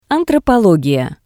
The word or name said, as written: Antropologiya